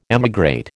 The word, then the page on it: emigrate: American English